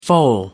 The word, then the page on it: foal: American English